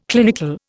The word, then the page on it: clinical: Indian